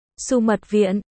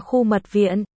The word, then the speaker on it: Xu Mật Viện: Sumi Doen, Su Mật Viện, còn đọc là Khu Mật Viện,